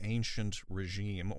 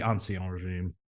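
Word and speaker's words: Ancien Régime: And this was in the last days of what was called the Ancient Regime or the Ancien Regime. I don't know. – Yeah, you can just say the Ancien Regime.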